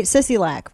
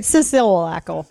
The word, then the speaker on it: Sisolak: And I said his name right, Sisolak, right? Sisolak.